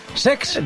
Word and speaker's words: Cesc: El Como de Cesc Fàbregas, que se es estrenó... Lo he dicho mal, es Sex.